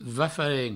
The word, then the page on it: va-farem: va-faren